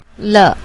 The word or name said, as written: le